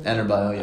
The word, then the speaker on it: Adebayo: How do you say his last name? – Adebayo, yeah. – Adebayo.